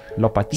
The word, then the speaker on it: Lopatin: Like Daniel Lopatin. Oh, is that how you say his name? – Lopatin.